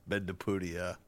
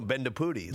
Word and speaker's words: Bendapudi: I believe it's Bendapudi. – Bendapudi. There's no A at the end. – No, Bendapudi.